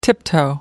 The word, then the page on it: tiptoe: American English